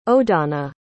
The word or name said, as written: odonna